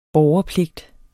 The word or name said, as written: borgerpligt